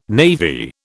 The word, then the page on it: navy: British English